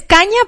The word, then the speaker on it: Kania: y es caña porque gente se, se escribe K A n I A ¿ok?